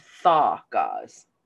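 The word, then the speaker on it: Dagaz: Thaw gauze. Yeah. Like, I literally don't think I've ever read that before. But it looks like D A G A S, but it's pronounced thaw gauze.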